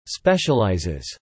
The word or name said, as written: ubiquitous